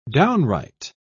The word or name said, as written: downright